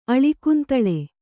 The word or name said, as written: ಅಳಿಕುಂತಳ